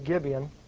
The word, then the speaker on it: Gibeon: And, of course, the ark was in Gibeon, I believe it was, Gibeon, that's how you pronounce it, where it was in the days of Saul and Samuel, there at the tabernacle that Moses built.